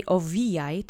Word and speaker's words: owijaj: The accent is on the middle syllable. Let's say owijaj together three times.